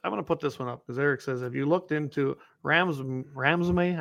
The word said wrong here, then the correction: Ramaswamy: I don't even know how to pronounce his last name. – Ramaswamy.